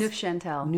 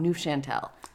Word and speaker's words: Neufchâtel: Choufenel? How did you say that cheese? – Neufchâtel. – Neufchâtel.